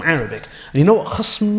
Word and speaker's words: khasm: And that's a mispronunciation of khasm, meaning it's the same word, but in Asian languages, it's difficult to pronounce the sukun.